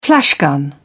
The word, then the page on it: flashgun: British English